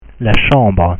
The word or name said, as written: la chambre